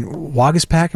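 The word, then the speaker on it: Waguespack: I mean, Wagaspak, am I saying the name correctly? Because yes.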